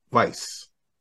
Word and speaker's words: Weisse: So I said that I was never going to pronounce this beer style wrong again. So I actually just looked it up. – Oh, so this is a Berliner style Weisse.